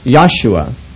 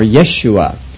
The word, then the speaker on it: Yeshua: Isaiah means the salvation of Jehovah, and etymologically it's not very different from Joshua, which would be pronounced Yahshua or Yeshua.